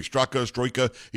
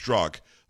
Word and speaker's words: Strzok: Congressman from the great state of Texas, who was questioning yesterday Christopher Wray, the FBI director, about why Brandon Strzok, and he keeps on saying Stroika, he changes the guy's name every time. It's spelled Stroika, but it's pronounced Strzok.